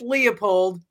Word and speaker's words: Leopold: Yep, it's just Leopold.